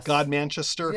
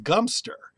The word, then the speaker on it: Godmanchester: God Manchester. – Yes. Gumster. – The BBC says it's pronounced Gumster.